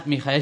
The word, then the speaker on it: Michael: No, lo he dicho aposta. He dicho, no es Michael Schumacher, es Mijael Schumacher.